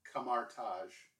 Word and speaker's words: Kamar-Taj: Kamar-Taj.